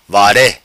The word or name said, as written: vare